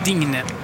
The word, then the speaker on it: Digne: Lucas Digne slutar också på E men uttalas inte sådär som Mbappé E.